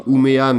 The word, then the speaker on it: Umeå: Alors ça s'écrit Umeå, mais il me semble qu'en suédois on prononce Umeå.